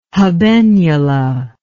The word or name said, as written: habenula